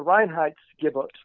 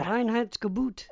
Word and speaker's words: Reinheitsgebot: Another reason says it was to simply keep the citizenry happy and the rabble from uh, revolting was the development of the Reinheitsgebot, which I hope I pronounced correctly. I never know if I do. Sounds great. – Yeah, yeah. Reinheitsgebot.